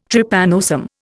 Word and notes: Trypanosome: Indian

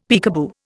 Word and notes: peekaboo: Indian

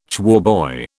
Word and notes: choirboy: British English

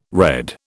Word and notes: redd: British English

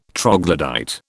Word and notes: troglodyte: British English

